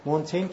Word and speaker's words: مونتین: از متفکرانی که خواهشم اینه تا هفته آینده دربارش حتما بخونین، یکی از مهمترین متفکران آره مونتینیه در واقع تلفظ فرانسوی، میشل دو مونتین